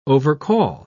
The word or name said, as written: overcall